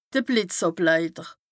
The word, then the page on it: de Blìtzàbleiter: Bas-Rhin; Strasbourg